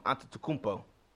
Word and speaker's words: Antetokounmpo: I like him and Antetokounmpo. That's how you pronounce his name.